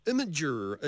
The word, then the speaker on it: Imager: So Imager, it's like image with an R on the end and no E. Did I pronounce that correctly? – You did indeed.